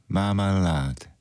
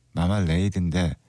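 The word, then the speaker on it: Marmalade: 레이디 마말라드 마말레이드인데 발음 마말라드 이렇게 하더라고요.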